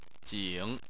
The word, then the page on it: jing: 3